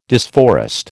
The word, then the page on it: disforest: American English